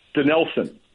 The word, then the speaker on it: Dinelson: Lamet Dinelson, am I saying it right? – Yeah, Dinelson, okay. Lamet. – Oh, is it? Say it again. – Dinelson. – Dinelson Lamet. Okay, I just opposite.